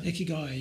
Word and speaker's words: Ikigai: Is it Ikigai? Ikigai, yeah. Ikigai. Yeah. It's I-K-I-G-A-I, I think is how it's spelled.